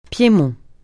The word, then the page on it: Piémont: francais